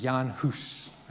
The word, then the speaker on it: Hus: John Huss, or maybe better pronounced, Jan Hus. Okay, Huss, by the way, was referred to as the goose father. Huss, I think in Czech, actually literally means goose. And so this is kind of a little nickname for Huss. I'll say Huss, even though I think the correct pronunciation is Hus.